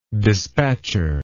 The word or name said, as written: dispatcher